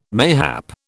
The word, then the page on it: mayhap: British English